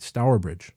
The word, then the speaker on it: Stourbridge: Stourbridge, England.